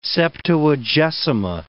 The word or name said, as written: Septuagesima